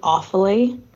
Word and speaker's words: Offaly: I need to correct you on your pronunciations. It's Sligo, Mayo, and Galway. Then Armagh, Tyrone, and Offaly. And then Leitrim, Donegal, and Laois.